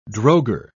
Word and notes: drogher: British English

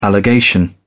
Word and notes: allegation: British English

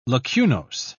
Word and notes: lacunose: British English

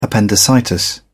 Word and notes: appendicitis: British English

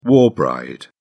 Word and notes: war bride: British English

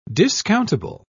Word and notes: discountable: British English

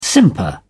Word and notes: simper: British English

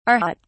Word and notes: arhat: British English

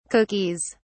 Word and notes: cookies: British English

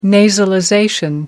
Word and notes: nasalization: American English

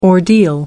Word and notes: ordeal: American English

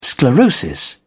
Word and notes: sclerosis: British English

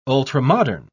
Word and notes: ultramodern: British English

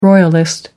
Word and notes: royalist: American English

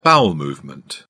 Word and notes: bowel movement: British English

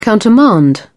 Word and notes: countermand: British English

